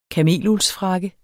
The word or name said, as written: kameluldsfrakke